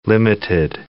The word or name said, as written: limited